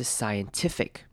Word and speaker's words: scientific: Science，好，那后面看到 T I F I C 这边两个 I 到念成短母音 I 的声音，短而急促的 i i i，所以这个字的念法是 scientific，注意重音在 t 这个音节上面，scientific。